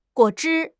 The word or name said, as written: guo3 zhi1